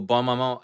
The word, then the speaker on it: Bonne Maman: Is it Bonne Maman? Bon Maman? – Oh, Bon Maman. It's the French one with the checker top.